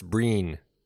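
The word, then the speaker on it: Brian: Did you see his first name is pronounced Breen? – Breen Gleeson.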